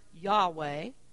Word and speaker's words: Yahweh: In Hebrew, it's four letters, YHWH, assumed to be pronounced Yahweh.